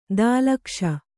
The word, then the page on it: ದಾಲಕ್ಷ: dālakṣa